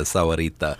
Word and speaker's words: Sahuarita: Am I mispronouncing Sahuarita? – If you've had a Spanish class, no. It's a Sahuarita.